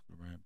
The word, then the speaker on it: Lauren: I guess I've been saying it wrong my whole life. – Oh, you thought it was Ralph Lauren? – Yeah. – No, it's Ralph Lauren. Bro. Damn. I mean, like Saint Laurent.